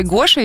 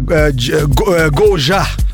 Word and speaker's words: Гожа: А это диджей Гоша или Гожа все-таки? – Пишется Гожа.